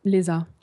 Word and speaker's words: ESA: On dit l'ESA, l'ESA, comment ça se prononce en fait ? – L'ESA. – L'ESA, d'accord.